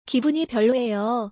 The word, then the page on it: 기분이 별로예요: Korean